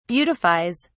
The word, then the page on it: beautifies: British English